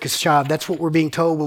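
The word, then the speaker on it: kashav (קשב): The Hebrew word there for paid attention is a Hebrew word kashav, which I probably just totally, brutalized by trying to say, but that's how it's spelled and that's how you pronounce it, is kashav.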